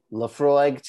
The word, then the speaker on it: Laphroaig: So as I have said, I am over here drinking Laphroaig 10-year-old Isla single malt Scotch whiskey. – Yay! – Yes, I pronounced all of that correctly,